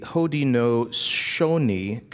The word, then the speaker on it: Haudenosaunee: This powerful philosophy comes from, now I'm going to have to slow my words down here to pronounce this word correctly, the Haudenosaunee Confederacy,